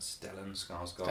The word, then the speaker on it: Stellan Skarsgård: Stellan Skarsgård.